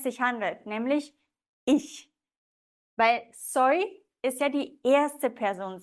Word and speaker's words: soy: Vielleicht noch das Y wird wie ein I ausgesprochen. Soy. Soy. Vale?